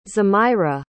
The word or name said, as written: zemirah